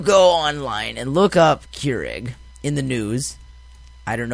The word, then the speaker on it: Keurig: Is it Keurig? – I would say more Keurig. – Keurig? – No, Keurig. Like, not as much of an E or egg, just Keurig.